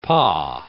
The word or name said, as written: pas